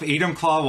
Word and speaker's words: Enumclaw: Currently lives in, I don't know if I'm going to say this right, Enumclaw? – Yep, Enumclaw, Washington.